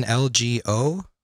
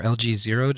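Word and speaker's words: LG-0: I acquired a early 60s Gibson LGO or LG0, depending on how you like to pronounce it.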